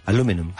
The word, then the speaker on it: aluminum: How do you pronounce aluminium in American English? – Aluminum. – Aluminum.